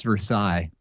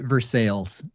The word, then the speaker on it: Versailles: So there is a Versailles, Indiana, but it's not pronounced Versailles. – Oh, no. How is it pronounced? – They pronounced it Versailles. It's pronounced Versailles, Indiana.